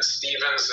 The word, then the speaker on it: Stephens: And Stevens is spelled Stephens.